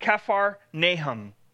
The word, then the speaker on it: Kephar Nahum: The formal name of Capernaum would be pronounced Kephar Nahum, or City of Nahum.